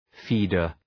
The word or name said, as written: feeder